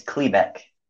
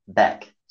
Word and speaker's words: Klebeck: It's Klebeck. – Say it again? – Klebeck. So, like Kleenex? – Klebeck. – But instead of next, you say Beck.